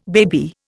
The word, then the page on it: baby: Indian